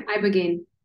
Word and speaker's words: Ibogaine: Yeah, Ibogaine.